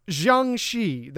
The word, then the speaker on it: Jiangshi: Well, then, as you know, it is pronounced Jiangshi. That's what we're going with.